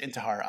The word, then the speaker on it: Intihar: Yeah, it's just Bryan Intihar. It's just my first name with a Y. Bryan with a Y, it's the right way to spell it. And it's Intihar, I-N-T-I-H-A-R.